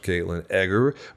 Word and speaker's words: Katlyn Eggar: Thank you so much, Katlyn Eggar with Quantum Assurance International. Did I say it right? – You did. Thanks so much.